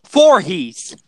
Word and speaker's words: Voorhees: There's also Jason Voorhees. – Voorhees from Friday 13th. It's pronounced Voorhees. – It is? – Mm-hmm. – It's spelled V-O-O-R. – Yep. But yep, and it's pronounced differently.